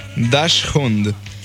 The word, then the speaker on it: Dachshund: Acá tenemos el Dashnush, el Dashnush. – A ver, déjame que lo vea. – A ver, ¿cómo se dice? – Dachshund.